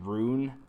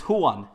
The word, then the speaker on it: Ruan: His name looks like Ruan, R-U-A-N. Some people were saying Rune. No one knew how to say it. And they just put out a little thing on their Instagram story and on Twitter. And it was just, hey guys, this is Juan.